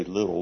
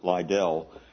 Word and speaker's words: Liddell: I think they pronounce his last name Liddell. We say Little, but Liddell, who was quite a runner.